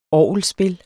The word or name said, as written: ORGELSPIL